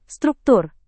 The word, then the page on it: struktur: Indonesian